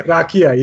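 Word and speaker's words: rakija: I think I'm most excited about the Radka at Belgium. – Rakia, yes. – Rakia, yes. – I always say it wrong.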